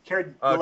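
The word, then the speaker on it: Dullea: But then, okay, so there's this whole thing of, okay, so Kier, how do you say his name? Delea? – Yeah. – Delea? – Kier Delea.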